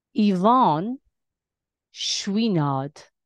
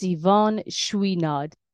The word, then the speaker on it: Yvonne Chouinard: Yvonne Chouinard. That's Yvonne Chouinard.